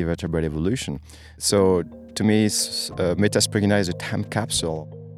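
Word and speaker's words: Metaspriggina: Metaspragina, is that how you say it? – Yep. – Metaspragina.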